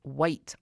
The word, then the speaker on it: Wight: White. Isle of White, but it's spelled W-I-G-H-T.